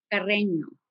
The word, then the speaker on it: Carreño: Now, Nelly, just for my sake, as we go forward in the podcast, is your name pronounced Carreno or Carreño? – Carreño.